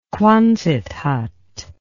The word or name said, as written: quonset hut